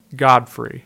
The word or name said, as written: Godfrey